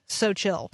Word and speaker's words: Seaux Chill: You just need to know that "Seaux Chill" is spelled as if the word "so" is French. – S E A U X. So chill.